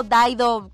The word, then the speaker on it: Dido: A ver, la original es de Dido, Daido, como se pronuncia esta mujer.